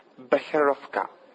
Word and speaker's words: Becherovka: Becherovka.